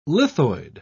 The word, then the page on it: lithoid: British English